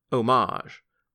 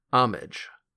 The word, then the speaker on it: homage: Oh, it's written as homage, but it's pronounced homage.